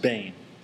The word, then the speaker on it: ben: It's a Hebrew word, "bane," but in English you would spell it B-E-N, but pronounced "bane."